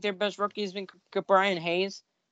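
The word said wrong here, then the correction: Ke'Bryan: or I think it's actually pronounced Key Brian, so I'll keep in mind that.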